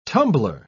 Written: tumbler

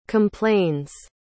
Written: complains

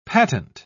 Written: patent

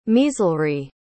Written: measelry

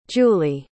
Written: julie

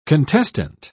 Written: contestant

